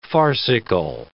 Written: farcical

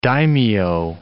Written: daimyo

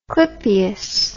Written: clipeus

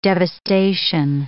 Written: devastation

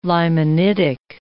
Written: limonitic

